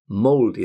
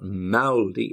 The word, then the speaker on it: moldy: Now, the pronunciation is moldy. It's not maldy, it's moldy. Moldy cheese.